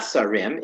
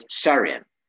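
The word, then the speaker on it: Sharim (שרים): but it's not Sarim, it's Sharim.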